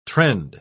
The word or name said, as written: trend